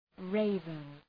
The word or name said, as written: raven